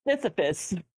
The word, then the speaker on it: Sisyphus: Syphilis? Never mind. – Sisyphus. – Never mind me. – Yeah, I think that's the pronunciation.